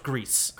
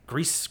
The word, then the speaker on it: Gris: It's pronounced Greece. – Greece? – I looked up a Reddit. I looked up a Reddit Ask Me Anything, and it's Greece. Greece, stain, Greece? No, yeah, Greece.